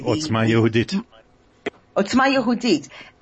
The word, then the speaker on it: Otzma Yehudit: Listen, Otzmama, how do you say it? The Jewish party. – Otzma Yehudit.